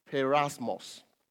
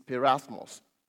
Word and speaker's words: peirasmos: And it's the word perasmos. It's perasmos. It's spelled P-E-I-R-A-S-M-O-S.